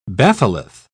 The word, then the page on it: batholith: British English